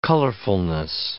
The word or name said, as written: colorfulness